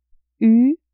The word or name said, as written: u